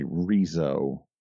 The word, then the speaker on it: Riso: apparently, Riso is the more correct way to pronounce it, as it is a Japanese company, Risograph.